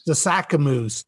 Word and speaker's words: Sasakamoose: I it's a hard name for me to pronounce. – Sasakamoose.